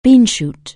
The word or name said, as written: beanshoot